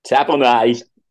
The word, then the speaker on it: Tapine: Can we say his name correctly? – Tapanay. – It's Tapanay. – Sorry. – Don't call him Tapine. I don't think he likes it.